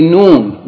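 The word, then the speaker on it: noon (نون): And the new letter is going to be noon, which is also very easy, like the N in English.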